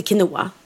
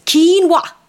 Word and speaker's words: quinoa: Ja, men nu är det så att det uttalas inte quinoa. Det uttalas quinoa.